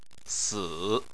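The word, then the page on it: si3: tone 3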